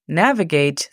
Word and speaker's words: navigate: Navigate significa navega. La pronunciación fonética es N, A, V, E, G, E, I, T.